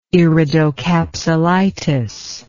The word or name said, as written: iridocapsulitis